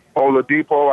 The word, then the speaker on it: Oladipo: Oladipo.